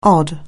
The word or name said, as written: odd